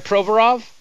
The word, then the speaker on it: Provorov: Provorov? – Yes.